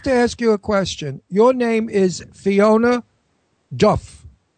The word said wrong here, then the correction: Dourif: I think it's, I pronounce it Dorf, like Dorf. – A door in the...